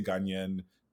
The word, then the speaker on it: Ghanaian: Ghanaian, or Ghanaian. I say Ghanaian. Ghanaian is another like pronunciation, Ghanaian. But Ghanaian. Okay.